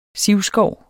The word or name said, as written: sivskov